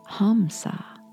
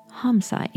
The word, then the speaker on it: Hamsa: Hong saw, the way we pronounce it today, many believe was originally pronounced Hamsa. Hamsa, H-A-M-S-A.